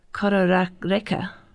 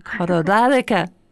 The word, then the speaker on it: Kororareka: And my first connection with Red Leap Theatre was I saw in Wellington Kororareka. I'm not saying it right. – Kororareka. – That's it.